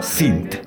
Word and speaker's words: Sint: La última película a comentar de esta lista de varias, Sint. Así se escribe, S-I-N-T, Sint, del año 2010.